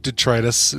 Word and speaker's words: detritus: So that, you know, turning it on for a while, it'll flush some big detritus. Is that detritus? – Detritus. That's right. – Detritus. Yeah. I did say it right.